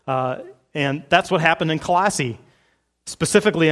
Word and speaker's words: Colossae: I have always wanted to say Colossae for this group that he writes, you know, to the Colossians. But apparently the city is pronounced Colossae.